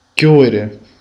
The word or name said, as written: chiudere